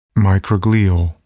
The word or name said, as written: microglial